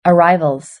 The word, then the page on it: arrivals: British English